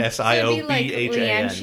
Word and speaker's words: Siobhan: S I O B H A N. – There's a fucking B in it, and it's pronounced Siobhan.